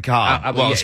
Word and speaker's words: Kolb: Not Kolb. No, Kevin Cobb. Cobb pronounced.